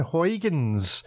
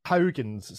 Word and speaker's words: Huygens: Huygens. So I would pronounce it Huygens from over here, the anglicised of it. But yeah, you know better, so we'll go with that.